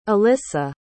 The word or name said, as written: alyssa